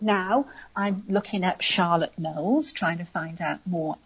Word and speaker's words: Knollys: Now, Charlotte Knowles, and the Knowles is pronounced Knowles, but spelt K-N-O-L-L-Y-S.